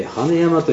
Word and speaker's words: 万年山: 継承地としては溶岩台地のこの羽山という、万年山と書いて羽山という発音です。